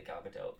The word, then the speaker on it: Gal Gadot: And again, I will say Gal Gadot because that is how she pronounces it, and that is the correct way to pronounce it.